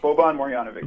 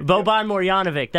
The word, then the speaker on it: Boban Marjanovic: And I want to make sure I say his name right. Boban Marjanovic. I believe that's how you say it. – Boban Marjanovic. – Boban Marjanovic. That's right.